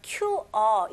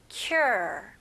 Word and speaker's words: cure: R는 힘을 주시고 R 발음이 혀가 이제 당기는 느낌이 나야 돼요. 그래서 QR 이게 아니고요. Cure 혀가 이제 꼬부린 느낌 나야 돼요.